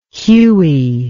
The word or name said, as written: Hughie